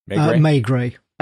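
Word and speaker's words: Maigret: McGray, Perot, Morse, or Barnaby? And I don't know if I pronounced that first one right, because I've never seen it before. – May Gray.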